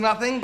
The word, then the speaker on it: nothing: Tenemos la primera sílaba acentuada yendo al TH muda. Nothing. Saca la lengua, muerde la suavecita.